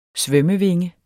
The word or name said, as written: svømmevinge